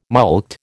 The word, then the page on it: mulct: American English